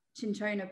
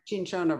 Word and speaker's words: Cinchona: Cinchona bark. – Cinchona bark, thank you, making it into quinine.